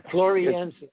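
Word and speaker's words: floresiensis: well, no, but we were talking about Alberta and, and uh, for more than 30 years, but now retired, spent decades researching Homo florent, how do you say it? Flor. – Yeah. Floriensis. – Floriensis. – Floriensis. Thank you.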